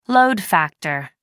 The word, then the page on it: load factor: American English